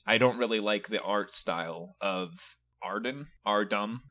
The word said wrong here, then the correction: Aardman: Aardman. I've been saying it wrong. It's Aardman.